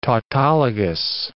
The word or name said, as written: tautologous